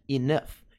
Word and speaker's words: enough: Essa palavrinha é muito, muito importante, muito utilizada e ela é curiosa por causa da pronúncia. A pronúncia é muito diferente da forma como você lê ela. Tá, naturalmente. Então, a pronúncia correta é enough.